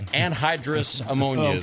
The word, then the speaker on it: anhydrous ammonia: One of the key ingredients in their meth-making process is, how do you pronounce that? Honduras ammonia? – I think it's anhydrous ammonia, okay. As I say it, yeah.